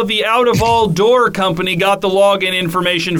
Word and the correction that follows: Utafall: Well, first of all, it's pronounced Utafall. It's Scandinavian.